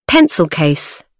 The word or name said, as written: pencil case